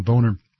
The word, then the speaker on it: Boehner: Now, you remember, he pronounces his name Boehner, so people don't call him Boehner. But we know, because the harmonica, the German harmonica maker, the famous harmonica, is Hohner, spelled the same way except with an H. And when two vowels go walking, the first one does the talking.